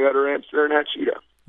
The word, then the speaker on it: Hachita: Hachita, yes, sir.